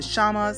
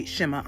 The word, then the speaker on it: Shema: So when I went to the Hebrew dictionary per se, it is, the word is, and some people pronounce it Shama, some say Shema. I'm going to say Shema on tonight.